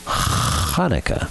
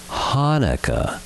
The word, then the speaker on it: Hanukkah: Just use your throat and say Ch, then attach it to the rest of the word, Hanukkah. It is not pronounced Hanukkah, no matter what Adam Sandler says.